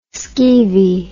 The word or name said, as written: skeevy